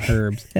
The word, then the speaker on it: herbs: Whatever micro region you're in at the time is the only region that calls them herbs. – You're saying the H is silent on herbs? – Yeah.